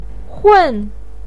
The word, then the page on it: hun: 4